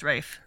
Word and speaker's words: Ralph: It's Rafe. It's pronounced Rafe.